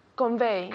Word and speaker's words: convey: Convey. – Convey, convey, not convey, it's un V, convey. Again? – Convey. – Así, muy bien. Casi como un F, yeah?